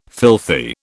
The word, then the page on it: filthy: British English